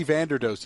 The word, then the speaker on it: Vanderdoes: Is it Vanderbilt or however you pronounce it? – Eddie Vanderdoes, the defensive tackle from UCLA.